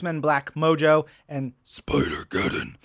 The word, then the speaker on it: Spider-Geddon: to correct your pronunciation. It's pronounced Spider Geddon.